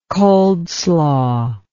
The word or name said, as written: coldslaw